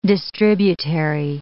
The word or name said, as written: distributary